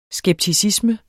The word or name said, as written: skepticisme